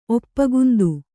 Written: ಒಪ್ಪಗುಂದು

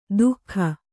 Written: ದೂಗ

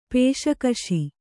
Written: ಪೇಷ್ಕಷ್

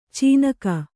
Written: ಚೀನಾಕ

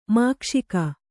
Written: ಮೋಕ್ಷಕ

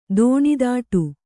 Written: ದೋಣಿದಾಟು

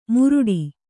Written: ಮುರುಡಿ

